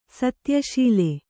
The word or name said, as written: ಸತ್ಯಶೀಲೆ